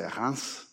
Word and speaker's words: Reims: And on the way to Auschwitz, she went by what you would say Reims, and you pronounce it Reims, but it doesn't matter.